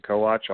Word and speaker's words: Kowach: My last name is spelled K-O-W-A-C-H. Or you can find me on Twitter, at Clinton Co-Watch.